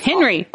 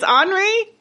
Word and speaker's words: Henri: The only one that I am positive of is it's Henry. – Henri. – It's Henri? He's Henri? – Henri.